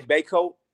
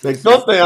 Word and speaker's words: Bacote: Is it Bakote? Are we saying it right? – Yeah, my family's originally from the East Coast. They say Baycote. – Baycote. – West is Bakote. – It's Bakote.